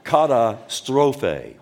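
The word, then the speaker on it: Katastrophe (καταστροφή): Katastrophe.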